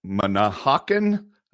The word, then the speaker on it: Manahawkin: And coming up in New Jersey at Manahawkin.